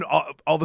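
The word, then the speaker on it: Alo: The emphasis went from like the ah to the low, and it was a low. And she goes, no, that doesn't sound right. Go back to Allo.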